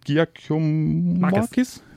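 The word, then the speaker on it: Giakoumakis: Und äh, der Eredivisie-Torschützenkönig, Georgios äh, Giacomakis, so wird er ausgesprochen, ähm, ja, der war sich angeblich ja mit äh, Werder Bremen einig, äh, doch mit Fenlo gab es jetzt keine Übereinkunft.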